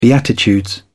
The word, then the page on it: beatitudes: British English